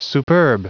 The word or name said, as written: superb